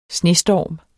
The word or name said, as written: snestorm